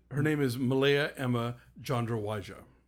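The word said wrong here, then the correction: Tjandrawidjaja: Thank you for pronouncing that, because I've been... – Sorry, Chandrawijaya.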